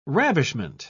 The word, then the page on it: ravishment: British English